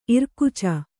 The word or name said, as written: ಇರ್ಕಚ್ಚೆ